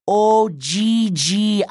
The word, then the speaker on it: Ogygia: Ogygia. – The girl pronounced it slowly, as if Leo were 5 years old. – I want to leave Ogygia, he said.